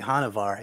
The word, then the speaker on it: Hanuvar: Is it Hanavar or Hanuvar? – I always say Hanavar. It sounds better.